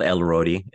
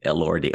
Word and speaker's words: Elordi: Oh, it's not Elrodi, Elordi.